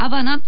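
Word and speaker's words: Habana: キューバでは H を発音しないのでハバナと書いてもアバナって読むんですよね。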